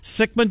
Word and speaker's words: Sikkema: And I hope I'm not saying his last name wrong, but Trevor Sikkema joins us. Trevor, how did I do on your last name, Trevor? Was I close? – You did great, Sikkema. You nailed it. You got the phonetic right.